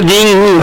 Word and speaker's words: दिन: नहीं ये दिन दिन ग नहीं है इसमें असल में कहने के लिए ग उच्चारण करते हैं मगर दिन वो है दिन